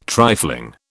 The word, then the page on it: trifling: British English